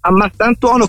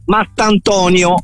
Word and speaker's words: Mastantuono: Mattantonio, ho sentito qualcuno. – Sì, la pronuncia, diciamo, che è stata storpiata da più parti e da più persone, sì, ma insomma l'importante è che poi Fulmini, che si ricorderebbe, sì, Toni, sì.